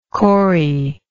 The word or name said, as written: corrie